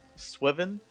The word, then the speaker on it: SWVVN: Also, we have an artist I plan to play at the end of the podcast named Swivin. It's spelled SWVVN.